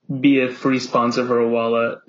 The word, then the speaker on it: Owala: I'm a big Owala guy as well. You're totally right. – Owala. That's how you pronounce it.